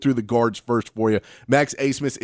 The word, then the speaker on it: Abmas: Spelled A-B-M-A-S, but it's pronounced Asemus.